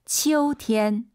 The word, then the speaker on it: 秋天: 방금 우리 청취자분이 게시판에 남겨주셨던 치우가 아니라 아이유 사이에 오 넣어서 치어우. 다시 한번. – 秋天.